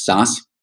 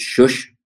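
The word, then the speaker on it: Szösz: The way my family pronounces it is Sas, but being in Eastern Europe, the way it probably should be pronounced is Shush.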